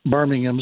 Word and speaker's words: Birmingham: When he brought his soccer team in England, in Birmingham, Birmingham is the way they pronounce it, he hired a guy, Wayne Rooney, one of the all-time great soccer players in the Premier League, fired him after 1 year.